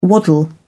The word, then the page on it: waddle: British English